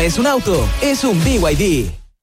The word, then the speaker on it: BYD: Se escribe BID, pero se dice BYD.